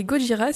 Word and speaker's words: Gojira: Et Gojira, c'est le romaji de Godzilla. Autrement dit, c'est l'écriture de la prononciation japonaise.